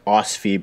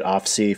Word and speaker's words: OSFI: It is pronounced OSFI, by the way, which is really weird because I used to say it OFSI for years, but I just realized that it's Office of the Superintendent, so the S is the second letter.